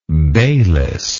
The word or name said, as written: Bayliss